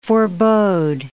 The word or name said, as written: forebode